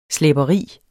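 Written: SLÆBERI

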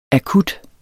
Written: akut

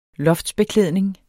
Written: loftsbeklædning

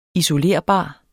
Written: isolerbar

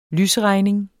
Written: lysregning

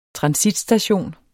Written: transitstation

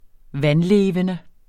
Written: vandlevende

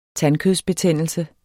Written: tandkødsbetændelse

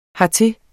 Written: hertil

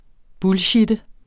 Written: bullshitte

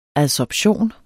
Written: adsorption

